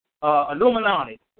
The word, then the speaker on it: Illuminati: And all of us have heard about the Amagilani. I'm pronouncing it wrong. Illuminati. I think I got it.